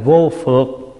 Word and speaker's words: vô phược: Cái thứ 9, vô phước giải thoát hồi hướng. Xin lỗi, đọc là vô phược.